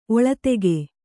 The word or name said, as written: ಒಳದೆಗೆ